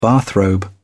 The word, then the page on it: bathrobe: British English